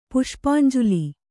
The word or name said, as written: ಪುಷ್ಪಾಂಜಲಿ